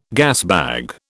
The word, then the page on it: gasbag: British English